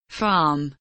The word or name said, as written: farm